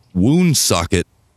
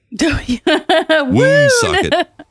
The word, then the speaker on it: Woonsocket: Oh, it was filmed in a place called Woonsocket, Rhode Island. Not Wound, but woon as in W-O-O-N. Woon socket.